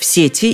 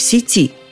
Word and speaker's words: сети: Если следовать его указаниям, предложном падеже только в предложном можно говорить и в сети, и в сети. Зато выйти по этому словарю можно только из сети. Знаю-знаю, всесильные компьютерщики когда-нибудь сделают свой вариант ударения общеупотребительным. Ну а я, пока этого не случилось, буду говорить только о сети